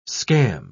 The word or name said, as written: scam